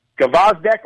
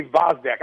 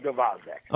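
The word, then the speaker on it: Gvozdyk: Gvozdek, or some people say Vozdek. I'm going with Gvozdek.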